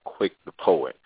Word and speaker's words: QuickThePoet: I also go by the poet name of Quick the Poet, and it's spelled all together.